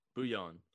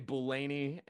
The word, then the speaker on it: Bouillon: Bouillon. It's French. Yeah. – Okay. Brock Bouillon. Brock. – Unless he gets on here in a couple of weeks and tells me that's not how you pronounce it, but. – Okay. Well, I hope so. I didn't want to. Dude, I was like, Brock, I was going to come on here and say Bulaney, and I'm so glad I asked.